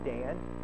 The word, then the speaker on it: Dan: He said, when you say your name, Dan, instead of trying to drop in the A in the end, going down, keep your tone even, keep it kind of going. That's how we say proper names.